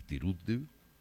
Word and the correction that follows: Turiddu: A ver, ¿cómo se llama? Yo no lo pronuncio bien. – Lola y Turiddu, sí, está bien.